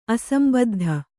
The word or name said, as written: ಅಸಂಬದ್ಧ